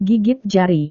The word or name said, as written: gigit jari